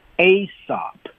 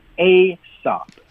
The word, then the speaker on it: Aesop: Aesop, Aesop. – It spells as A-E-S-O-P.